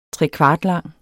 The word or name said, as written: trekvartlang